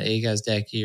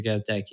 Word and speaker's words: Gazdecki: And then if you want to get a hold of me, I'm pretty easy to find on Twitter, at Gazdecki, or LinkedIn, Andrew Gazdecki, if you can spell that.